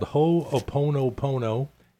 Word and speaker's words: Ho'oponopono: It's called Ho'oponopono, Ho'oponopono.